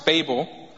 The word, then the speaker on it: Babel: Now, the interesting thing about this is that the next scene that we see in Babylon, now in Hebrew, in the Hebrew language, this is pronounced Babel, but it's the city of Babylon,